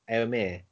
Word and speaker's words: Eomer: Eomer.